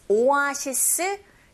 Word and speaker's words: oasis: Finding oasis, oasis 정확한 영어 발음은 oasis, oasis in the desert.